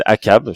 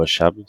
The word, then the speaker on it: Achab: il y a Captain Akab. Alors, je sais pas si vous le prononcez Akab ou Achab. C'est avec CH. – Moi, ah, je prononce Akab. aussi, ouais.